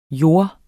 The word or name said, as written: JORDS